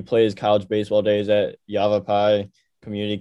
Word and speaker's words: Yavapai: Yavapai is exactly the right pronunciation for that.